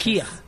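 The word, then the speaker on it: Kir: And it is Kerr Royal is made with champagne. – Delicious. – Kia. It's how it's pronounced.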